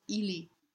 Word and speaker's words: Ili: It's as though through the L you say an E. Especially in this word, which is a contraction, the real word is Ili. and to maintain the correct number of syllables in the phrase, the poet has taken out the second syllable here. Because of this, the L must be palatalized and therefore very soft.